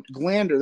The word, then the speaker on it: Glander: So Glander, that's how I pronounce your last name, right? – Yep.